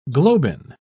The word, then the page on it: globin: British English